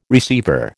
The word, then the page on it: receiver: American English